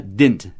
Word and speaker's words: didn't: Ou did not contraído, D-I-D apóstrofo N, didn't. Ou como a gente pronuncia, didn't. Esquece o segundo D, didn't.